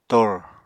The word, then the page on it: tor: Apatani